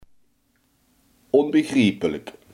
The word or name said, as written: onbegrijpelijk